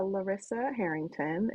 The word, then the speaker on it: Larisa Harrington: Yeah, Larisa Harrington, and Larisa Harrington, because everybody always autocorrect tries to spell my name wrong all the time too.